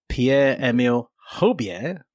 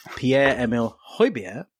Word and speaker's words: Pierre-Emile Højbjerg: Pierre-Emile Højbjerg. My first accurate pronunciation of this pod, and I should tell you, actually, I just butchered it twice and Milo's edited it, so it sounds like I got it right first time.